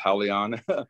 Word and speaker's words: Halion: No, I think that's an awesome fight and his name, you pronounce it Halion. I know it's spelled like that, but I don't get it either, but it's Halion.